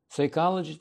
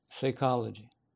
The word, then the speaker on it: psychology: Если читать просто как видится, то получится psychology. Но когда PS идет в начале слова, P не читается буква. Psychology. Это читается psychology.